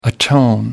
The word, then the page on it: atone: British English